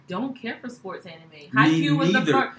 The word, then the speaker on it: Haikyuu: Haikyuu.